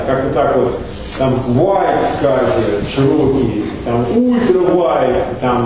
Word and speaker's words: SCSI: Почему-то произносится как SCSI, я не знаю почему, но везде в книжках пишут, что надо именно так и произносить.